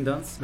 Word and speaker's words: Dunst: ¿Lo dije bien, Dunst? – Ah, sí, Christian Dunst. Ajá, muy bien.